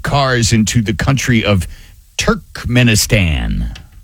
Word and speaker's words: Turkmenistan: Turkmenistan.